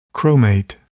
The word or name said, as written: chromate